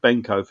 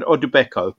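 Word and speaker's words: Odubeko: I apologise profusely to his family for my attempted pronunciation, but he's Ademipo Udubeko, and I keep wanting to add an N into that to make it Benko for some reason, but Udubeko.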